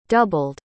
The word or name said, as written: doubled